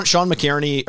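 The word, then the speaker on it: McInerney: I'm assuming, is Sean, is it McGurney? Is that how you pronounce the name? Is that Poopies? – It's Sean McKierney, or McKierney is Poopies.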